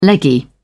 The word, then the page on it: leggy: British English